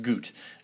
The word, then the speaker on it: Gutt: Gut, I guess his name is pronounced Goot, Jeff Goot, even though it's G-U-T-T.